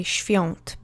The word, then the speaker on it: Świąt: So even though it's written Świąt, we would never say it like that. We would say Świąt because it's easier for our mouths to make that sound.